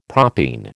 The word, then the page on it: propene: American English